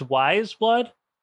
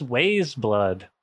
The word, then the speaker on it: Wise Blood: It's Wise Blood? I was like, what's Ways Blood?